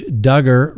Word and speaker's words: Duggar: Next on the docket, that's right, Josh Duggar rhymes with hugger.